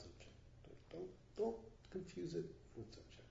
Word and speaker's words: Dzogchen: That's how you pronounce it, Dzogchen.